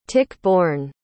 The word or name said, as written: tick-borne